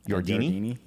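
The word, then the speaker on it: Yardeni: He is quoting and showing a chart from a research firm, Yardindi, and I probably murdered the pronunciation of this research firm. – I think he's a person. I think his name's Ed Yardini. – Yardini? – Yeah.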